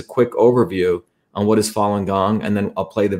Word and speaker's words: Falun Gong: It's pr pronounced Falun Gong.